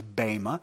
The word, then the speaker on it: Bema: The reason it's called Bema is because the proper pronunciation is Bema.